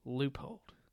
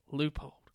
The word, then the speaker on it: Leupold: I don't know if they say it otherwhere, but here in Ozarks, a lot of guys call him Leopold, and it should be Leupold. That's how the commercials say it, Leupold.